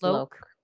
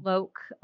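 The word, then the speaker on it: Louk: That's Shani Luick. I hope I'm saying that right. – I think it's Loke. Yeah. – Loke.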